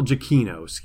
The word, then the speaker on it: Giacchino: Michael Giacchino, who is the composer for Rogue One, A Star Wars Story.